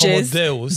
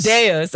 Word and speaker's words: Deus: Did I pronounce that properly? – I use the Latin pronunciation, which is Homo Deus. – Deus, okay. – But you can say Homo Deus. – I say the really bad, like non-accent Deus. Like Deus is great.